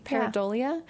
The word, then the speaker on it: pareidolia: Okay, this is a hard word to pronounce. Pareidolia? Pareidolia. Mm-hmm. That sounds right to me. Pareidolia.